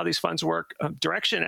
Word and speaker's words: Direxion: Just know that even though it's pronounced Direction, it's spelled D-I-R-E-X-I-O-N. So you might want to say Direction, but that's not how they pronounce it.